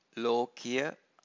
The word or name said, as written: lokiya